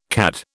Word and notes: CAD: British English

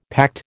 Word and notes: pact: American English

